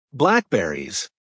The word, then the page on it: blackberries: American English